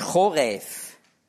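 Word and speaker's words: Choref (חורב): Bei Fußnote 12 steht hebräisch Choref. Da schreibt man Ch. Ja, V.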